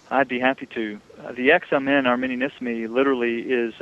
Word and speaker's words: Examen Arminianisme: correct my pronunciation, the Examen Arminianisme, is that right? Arminianisme? – Examen, okay. Arminianisme, that's correct.